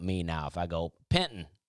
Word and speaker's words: Penton: It's important to know that the name is spelled P-E-N-T-O-N, like Penton. But if you say Penton, or if you try to pronounce the T at all,